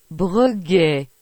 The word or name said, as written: Breguet